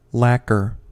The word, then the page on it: lacquer: American English